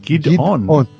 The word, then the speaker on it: Gideon: Gideon. – Break just it up Gideon? Into two syllables. – Two syllables. Gideon.